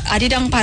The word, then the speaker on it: 아리랑: 그, 우리가 아리랑 발음할 때 어떤 분들은 이걸 꼭 쉽지 않으면. 네. 아리라로 드리죠. 라, 이러면 네. 안 돼요. – 라앙. 마지막에 응. 네.